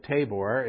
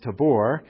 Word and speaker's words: Tabor: It's called Mount, we call it Tabor. It's supposed to be pronounced Tabor.